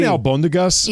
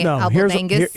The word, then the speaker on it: albondigas: He knows how to say it correctly, but he chooses to say Abelbangus. – Here's what happened. Here's what happened. I one time was reading something live on the radio, and the word was albondigas, mm-hmm. – but I did not know the word. So I said albodangas because I pronounced it like I was like kind of pronounced the whole thing.